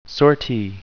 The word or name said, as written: sortie